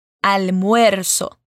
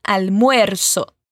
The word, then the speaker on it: almuerzo: Almuerzo. Bom, essa é uma palavrinha que alguns alunos sentem um pouquinho de dificuldade de pronunciar. Vamos por partes então. Al moer. So. Juntando tudo agora, almuerzo.